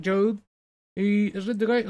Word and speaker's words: Job: Is that how you pronounce it? Job. Yeah, you pronounce it Job.